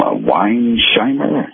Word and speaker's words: Weinsheimer: The document was signed by Deputy Attorney General Bradley, what's his name, Weinsheimer? Is that how you pronounce it? – Yeah.